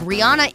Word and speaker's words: Rihanna: During an interview with British Vogue, Rihanna introduced herself with a whole different pronunciation. Instead of saying Rihanna, she said, "Hello, British Vogue, it's Rihanna." Fans reacted and are now questioning how to really pronounce her name. One fan wrote, "So we've been pronouncing Rihanna's name wrong the whole time." Another one said, "All of y'all who say you don't pronounce Rihanna with a hard ah on the Anna are lying unprovoked."